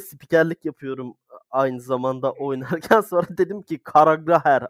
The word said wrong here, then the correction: Carragher: adama. Carragher diye okunuyorum.